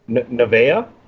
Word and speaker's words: Nevaeh: Alicia Edwards, Havoc, Kira Hogan, Kimberly, Kylie Rae, my girl, and boy, oh boy, how am I saying this one here? Nevaeh? Yeah? – Looking at you, phenomenal one. – You said it right, yeah.